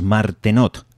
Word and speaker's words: Martenot: Bueno, pues si os habéis fijado atentamente, ahí oíais las ondas Martenot, que así se escribe, como os lo acabo de pronunciar. Ondas Martenot, acabado en T,